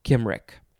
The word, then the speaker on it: Cymric: It is spelled capital C Y M R I C, or you can replace the C with a K because obviously it is pronounced Kimric.